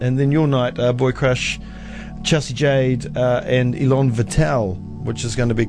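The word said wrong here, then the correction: Élan Vital: That's how you say it. Elaine Vital.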